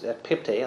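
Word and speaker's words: ekpipte (ἐκπίπτει): it's ekpipte, or ek, yeah, yes, ekpipte. I thought I said it wrong, but I did. I said it right.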